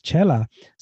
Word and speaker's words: Xela: So that X in Spanish produces the ch sound. And that's why it's spelled X-E-L-A. It still says Chella.